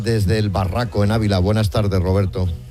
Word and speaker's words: El Barraco: Desde el Barraco, Ávila.